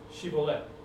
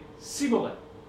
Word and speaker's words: Shibboleth: And they'd say, Okay, well then say, Shibboleth. And the guy would say, Siboleth. For he could not pronounce it right.